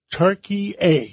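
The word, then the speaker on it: Türkiye: Now, I was taught by one of my friends, there's a new pronunciation for the country. Give us the, I used to call it Turkey. Now you call it? – Turkey. – Turkey A.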